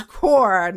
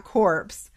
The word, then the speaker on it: corps: I was like, did you just pronounce the PNS? Yeah. – It's corps, not corpse.